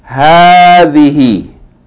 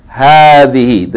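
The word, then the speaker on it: hadhihi (هذه): Okay, so this lesson, hadhihi, which is the feminine of hadha, it is pronounced hadhihi with a long vowel, even though the aleph is not written. The aleph is omitted in writing, but you have to pronounce it. Hadhihi.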